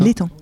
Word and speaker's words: Letang: Mais en France, notamment, on peut citer Renaud Letang, c'est ça ? – Le Tang. – Le Tang. L'Étang. Ouais,